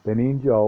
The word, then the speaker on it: Benigno: I don't know if I say this right. B-E-N-I-G-N-O. I call him Benigno. Benigno. That's it.